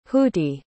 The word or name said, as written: hooty